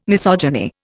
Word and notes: misogyny: Indian

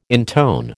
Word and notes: intone: American English

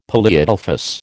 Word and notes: polyadelphous: American English

